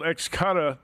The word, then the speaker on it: Xcutta: If you're looking for a more exciting way to bet, it's called Xcutta. That's spelled X-C-U-T-T-A, and it's the future of futures wagering.